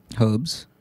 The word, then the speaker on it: herb: it's spelled H-E-R-B. – Herbs. – Bingo. In the U.S., we don't say that. That's a person's name.